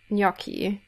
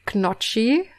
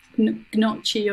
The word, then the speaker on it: gnocchi: I like my favorite is gnocchi. Actually, gnocchi and zucchini, because those are hard in German, and like you would find my favorite like pronunciation for gnocchi is knocchi.